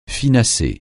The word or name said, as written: finasser